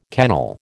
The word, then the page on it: kennel: American English